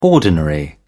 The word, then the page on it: ordinary: British English